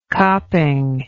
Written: copping

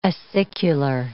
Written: acicular

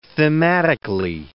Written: thematically

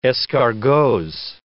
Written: escargots

